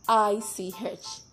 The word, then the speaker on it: sandwich: SANDWICH. Most times we say sandwich. It is wrong. It is pronounced sandwich. Sandwich. The D is silent.